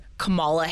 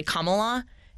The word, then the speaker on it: Kamala: Yes, no, the way I liken it is the same way everyone in the country would call her Kamala Harris, but it's actually Kamala.